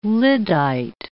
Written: lyddite

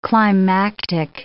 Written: climactic

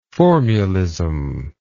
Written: formulism

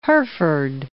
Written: Herford

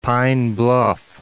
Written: Pine Bluff

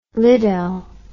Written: liddel

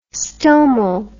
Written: stomal

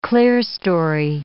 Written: clerestory

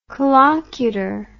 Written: collocutor

